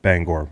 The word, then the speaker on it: Bangor: but the folks in Bangor don't care for it when you say Bangor, Maine. It's Bangor, Maine.